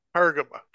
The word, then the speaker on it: Pergamum: we're going to move to the church of Pergamum. Hope I'm saying that right. Pergamum.